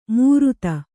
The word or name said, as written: ಮೂರುತ